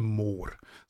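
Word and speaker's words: Mohr: Um, I'm, I'm going to pronounce it in German. It's called the Moor.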